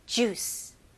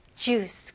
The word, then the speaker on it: juice: 영어로는 이음절이 아니고 이름절입니다. 그래서 juice. juice. 그리고 마지막에 모음 소리가 나면 안 돼요. juice 라고 하면 안 되고, 마지막 소리가 이 C 발음, S, C 이렇게 섞인 발음이죠. juice. juice. Okay? juice 가 아니고요.